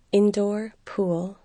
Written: indoor pool

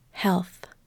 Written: health

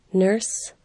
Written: nurse

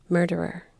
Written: murderer

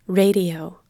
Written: radio

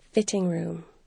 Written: fitting room

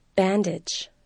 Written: bandage

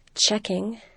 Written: chequing

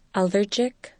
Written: allergic

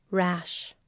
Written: rash

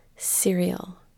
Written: cereal

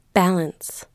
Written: balance

